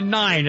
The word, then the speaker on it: Nein: It's a nine. It's neen nine with an I-E or an E-I. – How is it spelled? – It's spelled N-E-I-N. That's German for no.